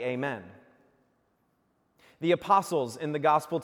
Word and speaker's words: Amen: It's pronounced actually Amen, and it means, so be it.